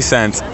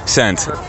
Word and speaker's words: Cent: My name is Vinny Cent. V-I-N-N-I-E, Cent. C-E-N-T.